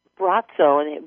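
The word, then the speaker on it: Braco: You can find, just if you Google Brazzo, but his name is spelled B as in boy, R-A-C-O, and then put a dash Cleveland.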